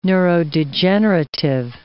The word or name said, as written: neurodegenerative